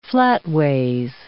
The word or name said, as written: flatways